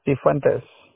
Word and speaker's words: Cifuentes: Cifuentes.